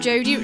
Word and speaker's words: Jody: That's under my name Jodie, which is spelled J-O-D-Y instead of I-E.